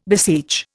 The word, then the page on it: beseech: Indian